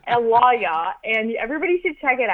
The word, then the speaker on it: Lawyaw: So the reason why I call this so just a bad revelation, amazing achievement is because I demoed this software called Lawyaw, and it's spelled L-A-W-Y-A-W. And it's kind of like, I was actually talking about it with my boyfriend and he's like, oh, like Lawyaw, like get a lawyer.